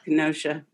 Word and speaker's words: Kenosha: So for today's entree, I shall be choosing the shooting of Jacob Blake in Kenosha, I believe that's how it's pronounced. – Kenosha, Wisconsin.